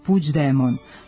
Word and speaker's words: Puigdemont: G u kombinaciji glasova IGD od izvornog Puigdemont, kako se na katalonskom piše ovo prezime, izgovara se približno našem Đ, ispred kojeg postoji prizvuk glasa I, koji se nedovoljno jasno čuje i lepi se na afrikatu, pa ga nije potrebno prenositi, a D još uvek nije postalo sporno.